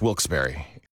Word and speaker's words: Wilkes-Barre: Now, I had heard that it was supposed to be pronounced Wilkes-Barre. – Wilkes-Barre Scranton's 97.9X. The only way to pronounce Wilkes-Barre.